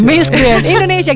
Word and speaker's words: Miss Grand Indonesia: Miss Grand Indonesia, gitu cara bacanya.